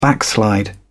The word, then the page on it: backslide: British English